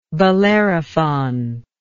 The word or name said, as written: Bellerophontic